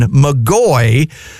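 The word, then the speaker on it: McGoy: But at this point, he had changed the spelling of his name to MC capital G-O-Y. So the same pronunciation, McGaughey, but an easier spelling for folks.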